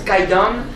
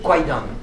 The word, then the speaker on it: Kwaidan: Et donc, on le prononce Kaidan, mais on l'écrit Kwaidan. Moi-même, avant de faire du japonais, je connaissais ce film, et donc comme je voyais K-W-A-I, j'ai envie de prononcer ce film Kwaidan.